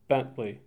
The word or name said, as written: Bentley